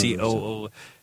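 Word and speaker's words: Connemara: There's one, Coonamara, something like that. It's Coonemara.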